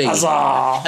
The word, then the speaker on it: Hazard: Like my favourite are people who pronounce Hazard, but they don't pronounce either the H or the D. – Hazard. – Hazard. Yeah, they leave the H and the D off of Hazard.